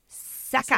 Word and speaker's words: Sakah: S-A-K-A-H. Saka. – S-A-K-A-H.